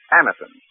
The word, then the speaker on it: Anacin: Remember the name, Anacin. It's spelled A-N-A-C-I-N.